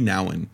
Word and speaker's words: Nouwen: Es de Henry Nouwen, H-E-R-N-I, ah, Henry, pero con I latina. Luego Nouwen se escribe N-O-U-W-E-N.